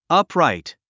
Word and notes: upright: ʌ́pràit; アプライト

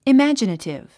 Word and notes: imaginative: imǽdʒənətiv; イマァジナティブ

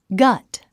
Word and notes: gut: ɡʌ́t; ガト

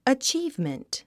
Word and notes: achievement: ətʃíːvmənt; アチーブメント

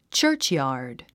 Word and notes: churchyard: tʃə́ː r tʃjɑ̀ː r d; チャーチヤード

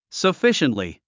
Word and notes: sufficiently: səfíʃəntli; サフィシェントリィ

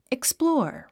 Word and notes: explore: iksplɔ’ː r; イクスプロォーァ

